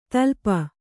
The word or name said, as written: ತಲುಪು